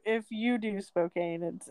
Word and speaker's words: Spokane: But it's just like Spokane, Washington. When people say it, when they read it and they say it out loud, they pronounce it Spokane. But it's pronounced Spokane.